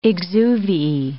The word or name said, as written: exuviae